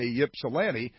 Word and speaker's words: Ypsilanti: I love Ypsilanti because you get to say Ypsilanti, and it's spelled Y-P-S-I-L-A-N-T-I.